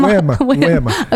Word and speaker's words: Wim Hof: You keep calling him William. okay. – Will William Wim, Wim. Wim. Okay. Like win, but Wim.